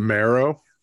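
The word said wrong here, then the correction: maero: Yes, the Mairo.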